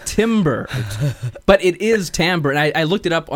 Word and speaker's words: timbre: There's a slight possibility we might be pronouncing the word timber, as in the musical term, incorrectly.